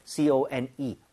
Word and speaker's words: cone: 근데 우리가 말하는 그콘 모양을 얘기를 할 때는 CONE, R 사운드가 아예 없이 깔끔하게 네.